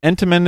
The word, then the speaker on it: Entenmann: You do not pronounce the second N. – Oh. – The emphasis is on the Ent, so Entenmann is correct.